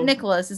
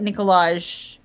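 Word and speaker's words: Nikolaj: It's not Nicholas. It's like Nicolaj. It's got a J.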